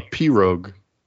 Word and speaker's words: pirogue: No, a pirogue. – That's what like I said. Pirogue. – Yeah. Like a pirogue. Nah, kind of, yeah. I mean, it's spelled the same, basically. P I R O G U H. I mean, G U E.